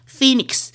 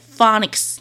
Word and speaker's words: Phoenix: 菲尼克斯 Phoenix，然后我的英文老师都念 Phonics，然后我就觉得奇怪，怎么老师你？